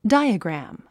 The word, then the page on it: diagram: dǽiəɡræ`m; ダイアグラム